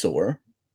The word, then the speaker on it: Saor: So there's a Gaelic word which is Saor, S-A-O-R, mm-hmm. which could easily be pronounced sore, but mm-hmm. is likely to cause problems because people will just try and spell S-O-A-R.